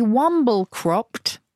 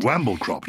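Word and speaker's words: wamble-cropt: To be womble cropped, which is spelled W-A-M-B-L-E and then C-R-O-P-T, womble cropped. – Is it all one word or is that all one word? All one word. – Womble cropped.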